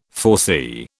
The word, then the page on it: foresee: British English